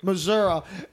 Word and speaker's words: Missouri: please pronounce the state right. – Missouri, it somehow Santa would always find a way to drop presents under the tree.